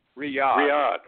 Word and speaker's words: Riyadh: Riyadh? – Riyadh, okay.